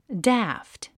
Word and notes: daft: dǽft; ダァフト

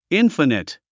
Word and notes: infinite: ínfənət; インファネト

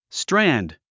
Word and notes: strand: strǽnd; ストランド

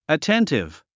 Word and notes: attentive: əténtiv; アテンティブ

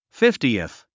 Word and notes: fiftieth: fíftiiθ; フィフティエス

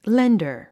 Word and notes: lender: léndər; レンダァ

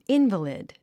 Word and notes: invalid: ínvəlid; インバリド

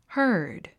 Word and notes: herd: hə́ː r d; ハード

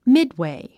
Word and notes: midway: mídwèi; ミッドウェイ

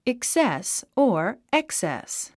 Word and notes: excess: eksés; エクセス

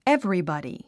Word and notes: everybody: évribɑ`di; エブリバディ

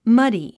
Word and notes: muddy: mʌ́di; マディ

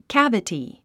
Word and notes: cavity: kǽvəti; キャベティ

